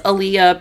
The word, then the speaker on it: Alea: Like, for me, example, as I said earlier, my name is Aaliyah, but it's spelled A-L-E-A, right? Yeah. On paper, people think it's pronounced Allie because there's no H at the end, so there's no indicator of the ah sound at the end. So when people read my name, they say Allie or Ollie or something like that.